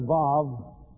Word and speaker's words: Vav: And this letter Vav sometimes also shows up in vocabularies as wa, but vav is the more accurate,